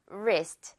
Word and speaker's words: wrist: 그래서 앞에 우리 이 W 오늘 묵음 처리, 유령 처리 할게요. wrist라고 읽겠죠? wrist.